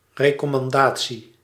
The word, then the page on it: recommandation: France (Paris); [ʁə.kɔ.mɑ̃.da.sjɔ̃]